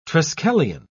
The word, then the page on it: triskelion: British English